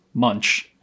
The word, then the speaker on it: Munch: which I've recently learned that that's how you pronounce Munch, Edvard Munch.